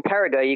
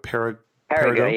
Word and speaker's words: Périgueux: it's P-E-R-I. – It's spelled the French way, so there's lots of letters at the end that have nothing to do with the pronunciation. – Yes, like most French words. Just put that to befuddle you. – So P-E-R-I-G-U-E-U-X.